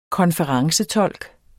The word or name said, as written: konferencetolk